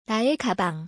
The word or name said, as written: 나의 가방